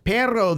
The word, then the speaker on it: perro: Perro with two R's is a dog. – Pero is how you say it, yeah. Pero or perro. – Pero. – Double R. – Yeah, if they really roll the R's, you got a dog.